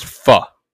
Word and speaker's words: pho: It's spelled pho, but it's pronounced pho.